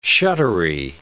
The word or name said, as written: shuddery